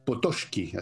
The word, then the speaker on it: Potocki: Y era un tipo muy especial, este Potocki, así se pronuncia en realidad,